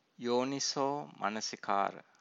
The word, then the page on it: yoniso manasikara: Pali